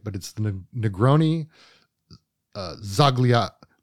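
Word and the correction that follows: Sbagliato: It looks like it's four syllables. It's three syllables. Negroni Zagliato.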